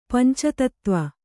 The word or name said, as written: ಪಂಚತತ್ವ